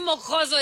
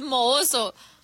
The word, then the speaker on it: mohoso: Y no se dice mojoso, es mohoso.